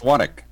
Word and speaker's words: Swatek: It's Swatek. – Swatek. – I asked him beforehand, and he's like, yeah, Swatek. It's like almost like one syllable, Swatek.